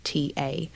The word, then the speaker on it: Koryta: I think that's why that's the correct pronunciation. It's Koryta.